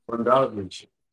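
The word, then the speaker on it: Bogdanović: How you say his name? – Bogdanovich.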